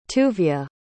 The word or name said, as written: tuvya